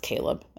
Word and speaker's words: Chleb: And it's not just the fact that his name is spelled Chleb and it's pronounced Caleb.